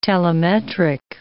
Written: telemetric

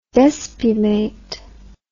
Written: despumation

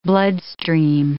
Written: bloodstream

